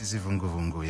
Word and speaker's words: Izivungu Vungu: And forgive my pronunciation, Izivungu Vungu. – Yes, Izivungu Vungu, yeah.